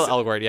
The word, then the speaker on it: Elgort: Of course, Elgort, yeah.